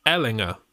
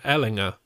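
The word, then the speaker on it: Ehlinger: Turns out that Sam Erlinger, their new QB, it's actually pronounced Ellinger, Sam Ellinger. And I've been saying that wrong since he was in college.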